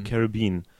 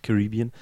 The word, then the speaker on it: Caribbean: Caribbean. – So ist angeblich die richtige Aussprache, auch wenn ich immer anders gesagt habe, egal. Wie jetzt? – Caribbean. – Nicht Caribbean.